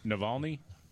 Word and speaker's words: Navalny: So Swalwell wants us to know, how do you pronounce this guy's name again? – Navalny? – Thank you. I was going to say Navalny, but I wasn't sure I was right.